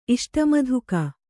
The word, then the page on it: ಇಷ್ಟಮಧುಕ: iṣṭamadhuka